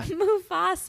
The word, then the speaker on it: Mufasa: Well, James Earl Jones is the voice in Mufasa. Is that how you say it? – Mufasa.